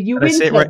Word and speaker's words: Uinta: Yeah, that's a big one for outsiders. It's the Uinta Basin.